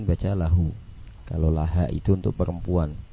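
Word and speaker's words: lahu (لهُ): Nah, jadi dibaca laha, ini salah. Muhammadun, kalau tulisannya seperti itu ya, antum bisa lihat nomor 9 tulisan dhamir ha ya, dibacanya lahu, dibacanya lahu. Muhammadun lahu. Kalau laha itu untuk perempuan, itu untuk perempuan.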